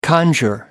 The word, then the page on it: conjure: American English